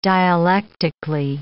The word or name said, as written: dialectically